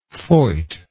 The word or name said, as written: voit